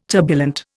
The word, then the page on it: turbulent: Indian